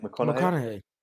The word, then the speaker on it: McConaughey: And the 3 that I've read are the Mike Tyson book, the Matthew McConaughey, I don't know how to say his surname. – McConaughey.